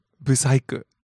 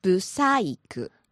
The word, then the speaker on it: ブサイク: So, ブサイク。 And pitch accent is on the I. You're gonna hit the high point at the I. – ブサイク。